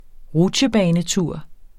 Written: rutsjebanetur

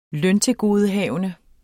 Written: løntilgodehavende

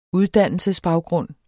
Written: uddannelsesbaggrund

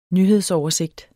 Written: nyhedsoversigt

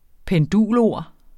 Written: pendulord

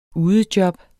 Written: udejob

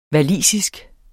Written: walisisk

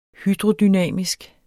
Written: hydrodynamisk

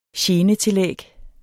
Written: genetillæg

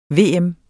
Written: VM

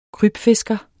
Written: krybfisker

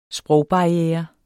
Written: sprogbarriere

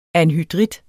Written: anhydrit

